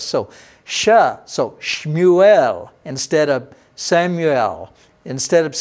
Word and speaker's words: Shmuel: In Hebrew, we would call his name today Shmuel. I have friends there in Israel who are named Samuel. We call them Shmuel. That's how you pronounce that in Hebrew.